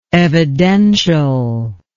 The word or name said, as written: evidentially